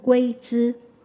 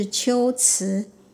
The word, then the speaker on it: 龟兹: 就是乌龟的龟跟兹是体大的兹，一般人容易念成龟兹，但正确的读音是龟兹。